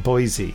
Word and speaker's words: Boise: He's putting in brackets that you pronounce it Boise. – Boise. Hello there, Del Boy. – It is Boise.